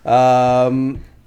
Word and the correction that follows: Renrenbit: Renrenbit? Yeah, that's how you say it. Renrenbit.